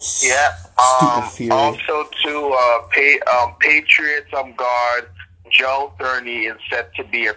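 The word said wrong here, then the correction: Thuney: Tooney, say it right. It's not Thurney. It's Joe Tooney.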